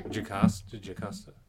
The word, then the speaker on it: Jocasta: Am I saying that correctly? – Yeah. – Jocasta. – Yeah, Jocasta.